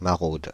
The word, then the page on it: maraude: /ma.ʁod/; France (Île-de-France)